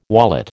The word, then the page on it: wallet: American English